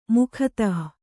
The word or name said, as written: ಮುಗ್ಧ